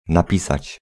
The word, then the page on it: skriva: /ˈskriːva/